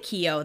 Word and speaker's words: Kyo: And then, I mean, if you pronounce it Kyo, that's fine, but it's like, it's just one, like, Kyo.